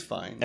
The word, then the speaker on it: Fiennes: Is it Fiennes? Fiennes? – I think it's Fiennes. – F-I-E-N-N-E-S.